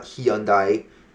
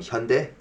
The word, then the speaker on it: Hyundai: If you want to learn how to pronounce that car, it's not Hyundai, it's Hyundai. That's how you pronounce it.